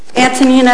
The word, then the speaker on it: Antonina: Antonina Allen, it's spelled A-N-T-O-N-I-N-A, Allen, L-L-E-N, it's 51 Foster Lane, Palm Coast, Florida.